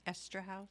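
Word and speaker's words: Eszterhas: Written by Joe Esterhaus.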